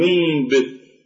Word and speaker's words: ينبت: فالنون ساكنة وجاءت بعدها الباء فنقلب هذه الباء إلى ميم فنقول ينبت. وكذلك أنبئهم، أنبئهم فهي بالأصل ينبت، يا نون ساكنة وباء، لكن بالنطق نقول ينبت، ينبت، لا لا لم ننطق بالنون، وإنما قلبنا هذه النون إلى ميم ولكن بغنة ينبت.